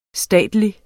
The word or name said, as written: statelig